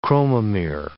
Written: chromomere

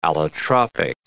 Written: allotropic

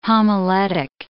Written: homiletic

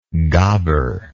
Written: gabar

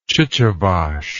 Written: chichivache

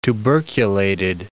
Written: tuberculated

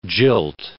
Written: jilt

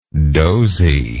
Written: doziness

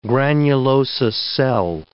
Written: granulosa cell

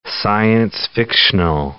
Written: science-fictional

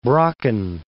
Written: Brocken

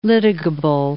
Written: litigable

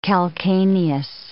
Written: calcaneus

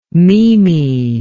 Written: mimi

